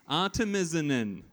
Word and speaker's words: Artemisinin: Look, there is a YouTube channel I looked up which tells you how to pronounce medical words, and that disembodied voice stumbles on Artemisinin. It's got too many inins. Artemisinin.